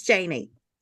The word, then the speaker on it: Janie: It's Janie. But Janie.